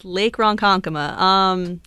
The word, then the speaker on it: Ronkonkoma: Lake Ronkonkoma? – Yes, Lake Ronkonkoma.